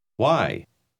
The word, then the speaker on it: why: Why? Why? – Note que WH se pronuncia con frecuencia como W.